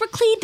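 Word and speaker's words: Laclede: And it is pronounced Raclede.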